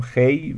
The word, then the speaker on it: خوی: خب دکتر انوری فرهنگ سخن زیر نظر ایشون نوشته شده، لغویان به اصطلاح ایشون نوشتن که خوی، خب میدونید هم خوی هم خی هم خی البته چیز داره، تلفظ داره، ولی ایشون گفتن احتمالاً حافظ خی میگفته،